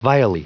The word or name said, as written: vilely